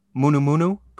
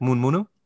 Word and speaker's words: munu munu: The word for morning is munu munu, pronounced in fast speech like moon munu.